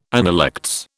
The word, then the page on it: analects: British English